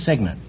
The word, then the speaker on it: cygnet: No, it's Signet. It's spelled C-Y-G-N-E-T, but it's pronounced Signet.